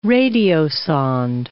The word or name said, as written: radiosonde